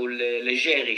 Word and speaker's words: Leggeri: Fabrice Leggeri or Leggeri, because I think his ancestries are Italian. So Leggeri should be the right pronunciation.